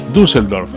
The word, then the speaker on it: Düsseldorf: Düsseldorf, así se pronuncia.